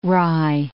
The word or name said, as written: rye